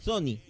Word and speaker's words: Johnny: Johnny. Pronuncie bien mi nombre, lo único que le pido. – Como las teles, Sony. – El resto hable Sony. Como el orto, porque. Sí. Pero mi nombre, dígalo bien.